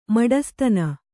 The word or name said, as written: ಮಡಸ್ತನ